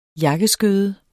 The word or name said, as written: jakkeskøde